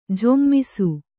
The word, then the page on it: ಝೊಮ್ಮಿಸು: jhommisu